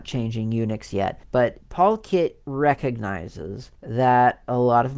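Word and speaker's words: polkit: Did I say it right? PaulKit. Policy. Yeah, PaulKit. Policy Kit is what it means. PaulKit is how it is spelt.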